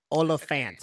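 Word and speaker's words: Olyphant: I just learned that I've been saying his name wrong the whole time. It's Oliphant.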